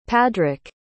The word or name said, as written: padric